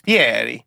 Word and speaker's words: Fieri: And then when he like got famous, he was like, okay, I'm going to use the actual pronunciation of my name, which is Fieri.